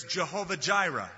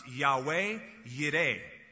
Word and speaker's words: Yahweh Yireh: There's Jehovah Jireh. Actually, it would probably be pronounced Yahweh Yireh, which means God is the provider.